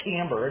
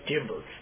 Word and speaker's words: timbre: And so it's a timbre, I was told in a book I was reading about music here recently, you're supposed to pronounce that timbre, and all my life I pronounced it timbre, you know, T-I-M-B-R-E it is.